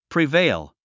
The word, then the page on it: prevail: privéil; プリベイル